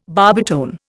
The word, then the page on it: barbitone: Indian